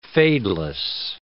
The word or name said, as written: fadeless